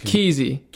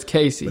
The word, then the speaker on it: Keesy: And by the way, it's spelled K-E-E. – K-E-E-S-Y. – S-Y. But he pronounced Keezy. – Keezy. – But it's Casey.